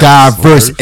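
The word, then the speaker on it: Dyverss: Yes. Diverse, exactly where his name is, Diverse. – How do you spell, is it spelled like? – Oh, it's D-Y-V-E-R-S-S. – Diverse, okay.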